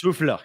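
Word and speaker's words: Höfler: Is it Hofler? – Lucas Hofler, yep.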